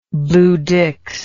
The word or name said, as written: bluedicks